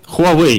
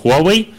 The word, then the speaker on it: Huawei: Немного о Huawei, естественно. Мы 2 недели не говорили о компании Huawei или Huawei, как правильно говорить по-китайски.